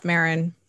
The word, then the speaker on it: Maron: It's Maron.